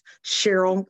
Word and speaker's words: Sherrell: His last name is spelled S-H-E-R-R-E-L-L. It looks like Sherrell, but it's actually pronounced Cheryl, like the girl's name.